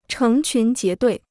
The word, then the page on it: 成群结队: chéng qún jié duì